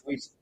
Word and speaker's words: Wyss: Okay, because so, I was always like, is it W-Y-S or do you pronounce it as a word? Does it mean something or is it initials? It's Wyss, yeah.